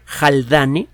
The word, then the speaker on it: Haldane: Si usted busca en la Wikipedia el término JSB Haldane, se escribe con H, Haldane, H-A-L-D-A-N-E, verá la trayectoria que tuvo este señor.